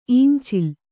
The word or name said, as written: ಈಂಚಿಲ್